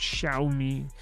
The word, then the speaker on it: Xiaomi: It's pronounced Xiaomi. It's spelled X-I-A-O-M-I.